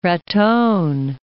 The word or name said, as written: Raton